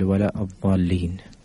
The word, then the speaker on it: الضالين: سؤال أبو زايد يقول هناك من يعني يقرأ سورة الفاتحة ويقول ينطق ولا الضالين ينطق يقول ولا الضالين. – الحمد لله. أولا طبعا ينبغي للمصلي عموما والإمام خصوصا أن يضبط القراءة. ففرق بين الضاد والضاء فرق بين الضاد والضاء.